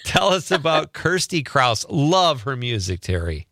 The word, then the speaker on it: Kirstie: So tell us a little bit about, is it Kirsty or Christy? It looks like it's Kirsty. – Oh, it's yep. spelled, am I crazy or is it spelled Christy? – No, it's spelled K-I-R-S-T-I-E, although I may have spelled it wrong in an email to you.